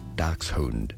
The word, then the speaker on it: Dachshund: In German, the name of this breed of dog is said as Dachshund. – Dachshund? – Dachshund. – Dachshund. In English, it is either said as Dachshund, Dachshund, or in the U.S.